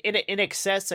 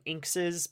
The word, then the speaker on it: INXS: Like in excess, I used to call them Inkses because it's INXS.